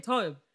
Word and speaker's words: TOBE: How do you say it? I say TOBE.